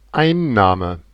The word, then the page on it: capture: /ˈkæp.t͡ʃəʳ/